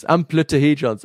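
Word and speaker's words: amplituhedrons: It's basically like amplitude. Just forget the amplitude, D. Amplitude and then just say hedron. – Amplitudehedrons.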